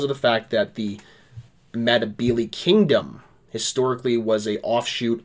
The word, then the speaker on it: Ndebele: It was kind of a British Empire anachronism that they called them the Ndebele, and it's still called Ndebele land now, but the way you actually pronounce it is more with an N. N-D-E-L-E-L-E. Ndebele. N-D-E-L-E. I can't spell it. Ndebele. B-E-L-E.